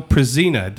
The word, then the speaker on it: Pruzina: With Marcy Coleman and Kyle Prezina.